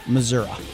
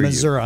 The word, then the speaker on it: Missouri: And then Missouri. And I am saying that right. – Yes, I hear Missouri.